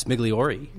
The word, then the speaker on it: Migliori: Well, I'll tell you, it's Italian. And so it's, the Americanized Migliori, hard G, hard E, traditionally Migliori, Migliori.